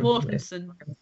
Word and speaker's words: Mortensen: Mortensen.